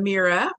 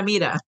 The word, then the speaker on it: Mira: I'm Mira Weinstein. – Oh, I'm sorry. Sorry for saying it wrong. If you speak Spanish, it's like Mira, Mira.